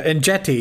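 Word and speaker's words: Enjeti: I, I, Angetti, is that how you uh, pronounce it? I, I, I, feel so bad now. Uh, but uh, that is uh, uh, and Jetty, yes.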